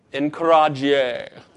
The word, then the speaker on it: encourager: How do you say this in French? Anybody know? I don't know French. Who would encourage? Encourager.